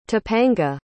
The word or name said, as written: tapanga